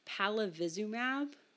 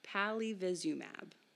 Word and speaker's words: palivizumab: Another um, point of prevention for developing severe illness, particularly in young children, there is a medicine, a drug called, I'm going to pronounce it wrong, palav- palavizumab. Palivizumab.